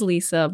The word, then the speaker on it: Lissa: It's really Lissa Lisa, Sweeney. But it's easier to remember how to spell Lissa. – It. No, my name is Lisa, but okay. It's spelled like Lissa, I guess.